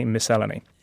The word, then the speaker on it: miscellany: So I managed to say, I stress the second syllable, which is a tongue twister for anyone else. But panic overtook me. Anyway, a long way of saying, yes, I say miscellany.